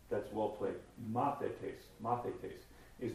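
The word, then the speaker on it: Mathetes (μαθητής): You want to use E for the A sounds, and so mathetes is what it looks like in English. mathetes is a disciple.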